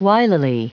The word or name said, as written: wilily